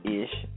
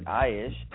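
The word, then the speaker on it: Ayesh (איש): Some people pronounce it Ish, as the rabbinic pronunciation follows. But, you know, we take that back and restore the A sound to the Aleph. I pronounce it Ayesh, and you look at Ayesh and Ayesh, Asha, or Aset.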